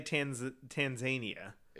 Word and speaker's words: Tanzania: Tanzania. Is it T-A-N-Z-I-N-A? – No. – Z-I-N-I-A? – T-A-N-Z-A-N-I-A. – Tanzania. – No, it's Tanzania. I've never heard anyone say Tanzania.